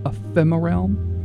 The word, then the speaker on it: Ephemeralm: That's called, let's see if I can pronounce it, Ephemeralm. Like ephemera and realm, but smushed together, Ephemeralm.